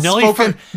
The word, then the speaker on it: Nelly Furtado: I have not Nelly Furtado.